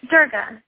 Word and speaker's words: Durga: My name is Durga. Thank you. – Gerda? – Durga. D-U-R-G-A, like the goddess Durga. – D-U-R-G-A.